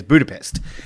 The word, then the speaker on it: Budapest: Okay, so everyone around the world generally knows this particular country as this particular city as Budapest, and its proper pronunciation is Budapest. So you don't. It's like a ch.